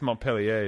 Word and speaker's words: Montpellier: Montpelier. Montpelier. That's how Americans pronounce it. Yes. – Yeah, but it's Montpellier is the French Yes.